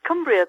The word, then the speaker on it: Cumbria: That's how I was going to say Cumbria, but I was going to let you pronounce it correctly. – So. Okay, it's Cumbria,